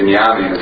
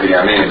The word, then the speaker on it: Binyamin: Because the Binyamin is spelled Mila'el and not Mila'ra. So it's Binyamin instead of Binyamin. – I love Spartan. – And that's an extension of the words to maybe, like, well, I already fought in the next, which is like 2 weeks, right? – Yeah. – Okay, meaning it will be a longer gullus. – It'll be a longer gullus. Very, very good. Only. It's not a spelling difference. It's a way of pronouncing the word.